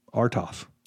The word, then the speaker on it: Artoff: This is Naomi Artoff. – How's it spelled? Tell me. – A-R-T-O-F-F. Artoff.